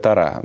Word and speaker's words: Тора: Принято так ударение. – Да? Да, ну хорошо. – У нас. Я знаю, что в иудаизме в некоторых традициях говорят Тора. Да, да, ну по-еврейски правильно, да.